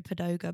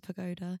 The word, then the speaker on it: pagoda: I always pronounce pagoda, padoga, but it's pagoda.